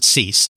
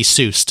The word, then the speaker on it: Seuss: but he does actually pronounce it Cease. I have interviews with him and with Bowman and other people, not that I've done myself, but that have been done, where his name is pronounced. And he does pronounce it Cease, despite that looking like it should be Seuss to some of us.